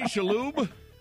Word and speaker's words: Shalhoub: Tony Shalhoub.